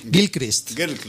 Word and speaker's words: Gilchrist: Hay que decir que hay algún personaje secundario como un buen amigo, como es el Kirlist. Es que esto, ¿cómo se pronuncia? Gilchrist.